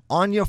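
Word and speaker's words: Anje: It's Germanic, so it's like a soft J. – Sure. Anya Falconwrath is a 1/3 vampire for red, black, one with haste.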